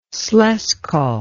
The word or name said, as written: slezsko